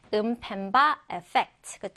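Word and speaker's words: Mpemba effect: Is the 음펜바 에펙트. 그쵸? 음펜바라고 발음을 해주시면 됩니다.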